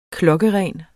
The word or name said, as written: klokkeren